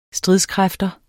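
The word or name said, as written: STRIDSKRÆFTER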